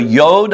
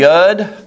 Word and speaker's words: Yud: This is called the Yod or the Yud. Y-O-D. Or it's pronounced Y-U-D.